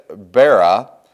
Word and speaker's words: Bera: These made war with Bera. Reading this quickly, if you're like me, you read it Berea, but it's not. It's Bera,